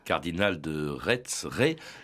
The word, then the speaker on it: Retz: Est-ce qu'on dit Ré ou Retz? Parce que alors, ça s'écrit R-E-T-Z. Hein. – Oui, ça s'écrit R-E-T-Z, comme le pays de Ré au sud de Nantes, d'où d'ailleurs il tire son nom, mais là-bas on prononce Ré. Alors lui-même, à la fin de sa vie, écrivait R-A-I-S, donc je pense qu'il faut se plier à la coutume.